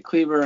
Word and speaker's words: Kleber: Maxi Kleber, I mean, at 4,200, he's priced, you know, maybe near the top of his fantasy output.